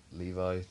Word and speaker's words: Levy: For Batgirl, I think Jane Levy, or Levi, depending on how you want to pronounce it.